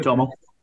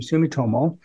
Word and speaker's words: Sumitomo: Sumitomo. – Thank you, Sumitomo, which is, out of Osaka, Japan.